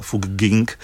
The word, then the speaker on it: Fugging: Ale vydrželo jim to 16 let a v roce 2020 se rozhodli pro starý hornorakouský přepis, kde se to vyslovuje stejně, jako píše v té Fugging.